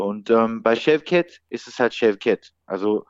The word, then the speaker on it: Chefket: Bei türkischen Namen wird immer die zweite Silbe betont. Chefket. Das heißt, es heißt Chefket.